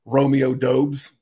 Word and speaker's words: Romeo Doubs: like Romeo Doubs.